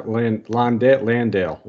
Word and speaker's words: Landale: And then, like you said, with Jakob and Jock, how do you say his last name? Landaline? – Jock Landale. Oh man, we're butchering this.